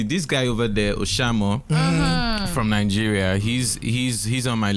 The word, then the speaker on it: Oshamo: Oshamo. O-S-H-A-M-O.